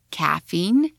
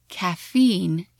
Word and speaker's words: caffeine: Sometimes Brits and Americans stress the opposite ends of a word. One will put the stress on the first syllable and one on the last. What's in my coffee in British English is caffeine and caffeine in American.